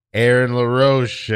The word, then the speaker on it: Aaron LaRoche: And this week in the studio, we have the mysterious, the elusive Aaron LaRoche, everybody.